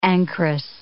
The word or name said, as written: ancress